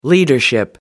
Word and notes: leadership: American English

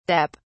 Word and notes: dep: British English

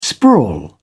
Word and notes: sprawl: British English

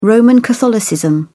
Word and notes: roman catholicism: British English

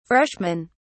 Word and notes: freshmen: British English

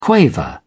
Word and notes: quaver: British English

